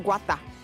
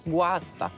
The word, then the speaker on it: Gouda: Liebe Mitcamper und liebe Mitcamperinnen, heißt es denn Gouda hier oder heißt es Gouda oder wie spricht man das aus? – Gouda. – Käse. – Was sagen wir? – Guata. – Ja, Guata. – Man sagt Guata? – Guata.